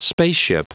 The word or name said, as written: spaceship